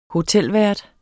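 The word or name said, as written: HOTELVÆRT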